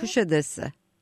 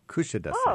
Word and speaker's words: Kuşadası: 2 days in Istanbul and one in Kuşadası. Is that how you say it? – Kuşadası. My hometown. – Okay. Kuşadası.